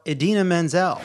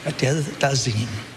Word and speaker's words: Idina Menzel: Next category, we have most butchered name. The nominees are John Travolta for Idina Menzel. – Please welcome the wickedly talented one and only Adele Dazeem.